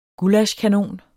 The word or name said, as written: gullaschkanon